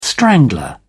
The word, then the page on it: strangler: British English